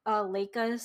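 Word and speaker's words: Laika: So Coraline, like you mentioned before, earlier in the episode, it was produced in a studio warehouse, Leica Studio. Is that how it's pronounced? – Yeah, you got it.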